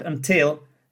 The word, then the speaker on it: until: So in the word until, the second syllable is stressed. The first one has a schwa sound in it. Schwa sound is until.